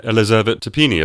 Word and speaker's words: Elisavet Tapini: Yeah, Elisavet Tapini, if I pronounced that correctly.